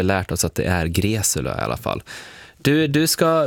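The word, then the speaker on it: Greczula: Ja, du uttalade det helt rätt. Det var ju väldigt roligt under Melodifestivalen när han var med där, så var det en reporter på Ekot som envisades med att uttala hans namn Greksula.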